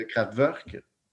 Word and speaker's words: Kraftwerk: Kraftwerk. Oui, je le prononce avec un V, parce que c'est supposé être de même. C'est allemand, c'est comme ça.